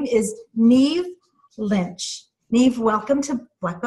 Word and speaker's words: Niamh: Neve. Her name is spelled N-I-A-M-H, but in Irish, it's pronounced Neve.